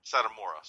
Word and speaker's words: Satomura: And then Mandy Rose, and because they're going to get it off of Miko Satomayor. Or Satomura, sorry.